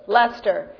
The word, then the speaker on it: Leicester: Is that how you guys pronounce that? Leicester. You guys. It's like Worcester or something.